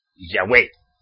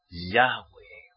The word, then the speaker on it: Yahweh: Dicen Yahweh. El acento en la E. No es así. Yahweh.